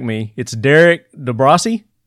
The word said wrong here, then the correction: DeBrosse: Yeah, you screwed it up. It's DeBrosse. We Americanized it. – DeBrosse. You dropped the long E. – We dropped the Frenchism on it.